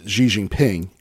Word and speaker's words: Xi Jinping: So it's pronounced, when it's the Chinese president, is Xi Jinping.